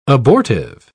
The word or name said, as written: abortive